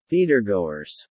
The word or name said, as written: theatergoers